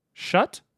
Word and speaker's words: Schut: Shut? S-C-H-U-T.